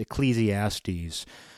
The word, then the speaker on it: Ecclesiastes: however you say it. I believe it's pronounced Ecclesiastes.